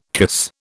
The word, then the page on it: kiss: British English